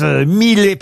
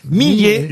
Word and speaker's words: Millet: On prononce bien Millet. J'ai eu un doute à savoir est-ce qu'il fallait Millet. Pas dire Millet, car certains disent Millet par erreur, mais il faut bien dire Millet, le fameux Millet. Angélus de Millet, et évidemment son tableau. – M-I-2-L-E-T. – Oui, M-I-2-L-E-T.